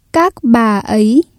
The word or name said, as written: các bà ấy